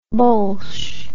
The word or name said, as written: bulsh